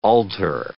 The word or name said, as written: altar